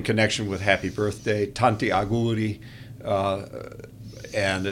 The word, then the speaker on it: auguri: Auguri. – Auguri.